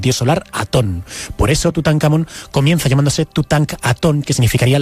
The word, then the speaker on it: Tutankamón: La Real Academia de la Lengua dice que hay que pronunciarlo y decirlo como Tutankamón.